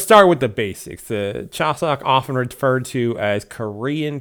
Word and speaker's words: Chuseok: Uh, it is the the Chuseok Festival, and I actually uh, did pronounce that right.